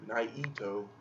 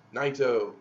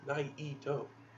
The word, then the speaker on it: Naito: I was kind of mad because everyone was just doing two syllables, and... The Japanese people do three syllables with his Naito. – They go Naito.